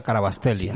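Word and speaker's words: Kvaratskhelia: Pues obviamente un balón cruzado y Carabastelia, ¿sí es que se pronuncia? – Sí, Bicha Carabastelia.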